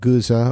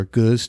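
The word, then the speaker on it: Gueuze: We started out with the first one, I think, was called Guza or Goose, depending on how you want to pronounce it.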